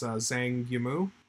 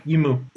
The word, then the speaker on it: Zhang Yimou: I know his name, but it's Zhang Yimou. – Yimou is, I mean, he's so good.